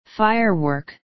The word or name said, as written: firework